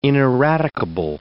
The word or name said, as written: ineradicable